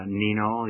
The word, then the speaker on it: Neno: While you were then serving the Lord as a chaplain, that you saw the need for Nino, your magazine, or have I pronounced that correctly? – That's exactly right.